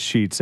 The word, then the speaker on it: Scheetz: Well, I'm at Chris Scheetz. Everybody spells my name wrong. S-C-H-E-E-T-Z.